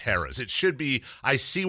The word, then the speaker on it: Harrah's: And he called it Haraz. And I went, oh, yeah, no, it's spelled like that. It's actually Harris.